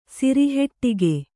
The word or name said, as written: ಸಿರಿಹೆಟ್ಟಿಗೆ